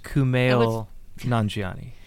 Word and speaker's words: Kumail Nanjiani: It's pronounced Kumail Nanjiani.